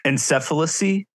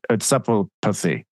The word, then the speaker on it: encephalopathy: encephalacy. – Encephalacy. Encephalopathy.